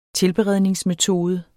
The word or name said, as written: tilberedningsmetode